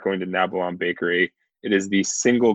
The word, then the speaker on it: Nabalom: Nabilon. N-A-B-A-L-O-M.